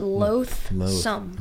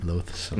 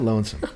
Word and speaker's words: loathsome: It's like you're saying oath, but loathsome. – Loathsome. – It means like a person who causes like hatred or disgust. – Yeah. Loathsome. You got to say it like that. Loathsome. Not lonesome.